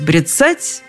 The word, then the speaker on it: бряцать: Брецать, брецание – Именно такое ударение приводит толковый словарь Даля.